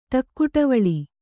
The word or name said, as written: ಠಕ್ಕುಠವುಳಿ